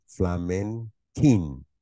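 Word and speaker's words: Flamenquín: It's Flamenquin. Q-U-I-N at the end. Flamenquin. That's how we pronounce that.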